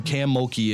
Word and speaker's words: Kim Mulkey: But no, she's known, if you don't know who Kim Mulkey is, or was that the pronunciation right? Yeah, she dresses up in a variety of colors every game.